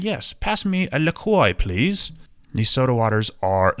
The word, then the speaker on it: LaCroix: It's pronounced LaCroix, but it's spelled L A C R O I X.